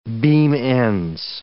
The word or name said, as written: beam-ends